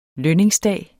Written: lønningsdag

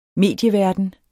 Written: medieverden

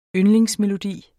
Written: yndlingsmelodi

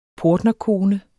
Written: portnerkone

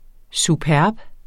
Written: Superbt

